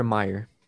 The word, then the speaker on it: Mayer: I never know if it's the Mayor or Meyer.